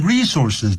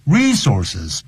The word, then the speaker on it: resources: Number 13, the Human Resources Department will be screening job applicants this week. 这个我们人资部 resources, 不要念成 resources, 重音在第一音节。